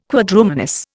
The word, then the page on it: Quadrumanous: Indian